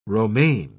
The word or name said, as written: romaine